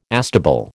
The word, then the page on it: astable: American English